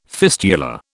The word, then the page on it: fistula: British English